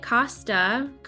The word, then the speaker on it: Costa: I have Julie Costa. Did I say that right? – Costa, you said it right. – Awesome. Costa on the podcast today.